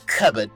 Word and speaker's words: cupboard: Found this back in my cupboard. – Cupboard. Right back to 1942. – God, 22. Exactly. See, and wow, that's why it's funny. – He pronounced the P and the B.